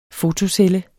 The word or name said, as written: fotocelle